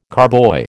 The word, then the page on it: carboy: American English